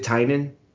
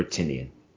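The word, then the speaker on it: Tynion: Is it Tynan or Tinian? How does he say it? Because we hear it so many ways that I always mispronounce it. – Tinian is what I seem to remember. I'm obviously bad with names.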